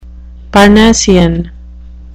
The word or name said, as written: parnassian